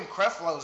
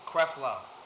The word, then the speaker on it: Creflo: His name Creflo. – Creflo? I don't fucking listen to him. I've never heard anyone actually say his name. – Creflo. – Okay. – It's the way it's written.